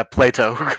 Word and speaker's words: Plato: Did I say it right? Or is it Plato or Plato? I'm sorry, I forgot to ask. – Yeah, Plato.